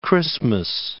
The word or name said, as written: Christmas Island